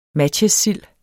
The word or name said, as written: matjessild